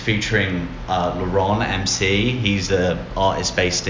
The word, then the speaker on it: Laron: Laron MC.